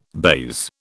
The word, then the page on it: baize: British English